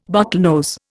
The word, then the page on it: bottlenose: Indian